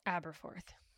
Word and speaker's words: Aberforth: Aberforth.